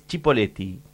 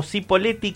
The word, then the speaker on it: Cipolletti: Por supuesto, que bueno, la pronunciación correcta en italiano es Cipolletti, con CH, ¿sí? Con CHI. El uso acá en Argentina se popularizó como Cipolletti con S, lo pronuncia por acá, mira este informe, o con C, ¿sí? Por la influencia del español, donde la C antes de la I suena como S, ¿no?